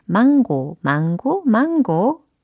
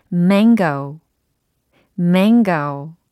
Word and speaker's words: mango: 망고, 망고, 망고. 요거는 아니겠죠? 잘 들어보세요. mango, mango. 뭔가 코 소리가 이렇게 맹맹하게 나야 됩니다. 예, mango 해보세요. mango, 코 맹맹이 소리를 내면서 mango.